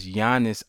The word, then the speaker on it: Giannis: It's Giannis Antetokounmpo. – That's not what I read. – But that's how you pronounce his name. – Giannis? That's a G. – But the G is silent.